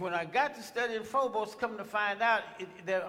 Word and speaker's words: phobos: The word that we find here, fear, in the New Testament is pronounced Phobos.